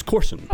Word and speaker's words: Corson: It's Corson. Okay. Like golf course with an N.